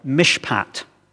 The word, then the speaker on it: mishpat: The word translated justly is the Hebrew word, forgive my pronunciation here, but it's pronounced something like mishpat.